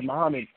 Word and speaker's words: Muhammad: Oh, So if you want to find me on YouTube, you could type in, my name is Farad Muhammad. It's spelled in Arabic, so my first name is spelled S-A-R-D, and my last name is spelled M-U-H-A-M-M-A-D.